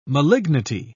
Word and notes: malignity: British English

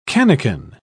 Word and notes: cannikin: British English

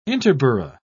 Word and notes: interborough: British English

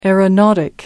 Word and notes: aeronautic: American English

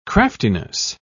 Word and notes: craftiness: British English

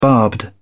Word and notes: barbed: British English

